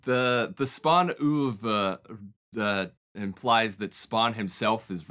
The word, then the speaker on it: oeuvre: oeuvre? – Oeuvre.